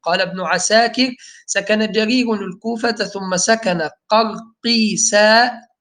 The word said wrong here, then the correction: قرقيسياء: هي الياء اللي بعد القاف تنطق ولا هي ياء مادية؟ – قرقيسياء. – تمام. قرقيسياء.